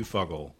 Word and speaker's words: Fuggle: Number two is Fuggle, and number three is the blend. No, wait. Did I say that right? – I don't know. – Yes. Yeah. Number one, EKG. Number two, Fuggle.